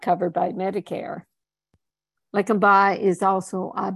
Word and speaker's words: Leqembi: How do you pronounce that, Bob? Leqembi? – Leqembi. – Leqembi. There's Bob, my linguist.